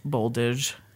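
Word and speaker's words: Boldizs: A Z and an S together are pronounced like a Z sound. – Oh. – So his last name is Boldage.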